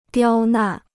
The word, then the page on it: 刁难: diāo nàn